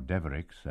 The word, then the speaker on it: Devereux: Jack Devereux, or Devericks, as this Norman name is pronounced locally, is a fisherman who has been singing the carols for over 50 years, thus maintaining a family tradition going back many generations.